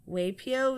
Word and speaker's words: Waipio: So I have Waipo Valley, and Niue. – Just redo it. Figure out how to pronounce those. – How do you pronounce that? Waipo Valley, Niue. – Waipio Valley, Niue Beach.